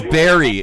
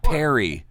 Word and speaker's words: Barry: No, his name is Barry. It's spelled B-A-R-R-Y. Not, where did you get that? – It's not Perry.